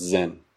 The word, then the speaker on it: zen: And then that word, that character carries over into Japanese, but instead of pronouncing it chan, it's pronounced zen.